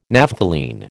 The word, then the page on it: Naphthalene: American English